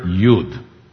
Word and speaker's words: Yud: In other words, the letter Yud, pronounced like Y in Hebrew, Yud is represented with a hand, in Hebrew, Yad.